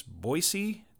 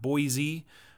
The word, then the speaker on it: Boise: And one more thing, remember, it's Boise, not Boise.